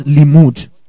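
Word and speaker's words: limmud: The word learned here in the Hebrew is the word limud. I'll spell it for you. L-I-M-M-U-D. L-I-M-M-U-D. Limud.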